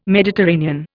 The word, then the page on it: Mediterranean: Indian